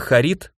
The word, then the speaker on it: Харид: Диковинное для меня имя Харид произносилось с ударением на второй слог, а фамилия Дирна имела ударение на первый и явно о чем-то напоминала.